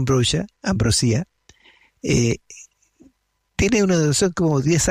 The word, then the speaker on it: Ambrosia: Este grupo que se llama Ambrosia, en castellano, sí, en español diríamos Ambrosia porque se escribe así, que en inglés se debe pronunciar Ambrosia,